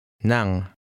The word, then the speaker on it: ng: When the middle word stands alone, it is pronounced nang.